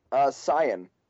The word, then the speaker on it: Saiyan: What's the proper pronunciation? – Saiyan. In the Japanese, it's Saiyajin. So, Saiyan would be the more correct.